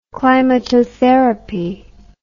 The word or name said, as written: climatotherapy